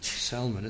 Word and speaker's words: Salmon: And the L is sounded, although it's spelt the same as salmon.